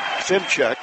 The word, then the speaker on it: Szymczak: St. Mary's, by the way, is coached by Craig Simcheck. Now, you would not pronounce it the first time, second time, third time, fourth time, maybe not even the fifth when you look at his name. S-Z-Y-M-C-Z-A-K, Craig Simcheck.